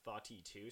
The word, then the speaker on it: Thoughty: Thoughty 2. So it's spelled T-H-O-U. It's spelled like thought. – T-H-O-U-G-H-T-Y? – Yep, and then...